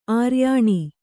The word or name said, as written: ಆರ್ಯಾಣಿ